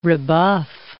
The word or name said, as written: rebuff